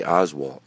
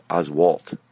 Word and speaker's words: Oswalt: I say his name, can you believe, I know I say Oswalt, it's Oswalt. Jeez, get it right.